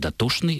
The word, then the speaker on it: дотошный: в современном русском языке уже почти не осталось, хотя немножко осталось, слов, в которых сочетание ЧН в старых употребительных словах произносится как ШН. То есть дотошный – это бывший доточный.